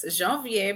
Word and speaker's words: Janvier: Yes, Janvier, but Janvier.